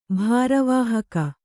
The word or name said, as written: ಭಾರವಾಹಕ